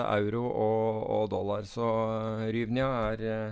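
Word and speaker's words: hryvnia: Det begynner på H, og ikke på, på U av en eller annen grunn. Men uh, du kan prøve å uttale det, du. Men det er en uh, eh, eh. Ryvnia. Ryvnia. Det tror jeg er ganske nært.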